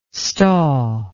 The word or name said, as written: staw